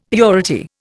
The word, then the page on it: purity: Indian